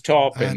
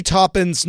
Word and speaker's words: Taupin: Well, Bernie Taupin's memoir is called It's Topin," right? Have I been mispronounced? – Yes, sorry didn't want to be mean and correct you, but it is Taupin.